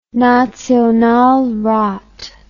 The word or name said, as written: Nationalrat